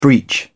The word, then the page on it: breach: British English